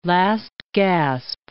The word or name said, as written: last-gasp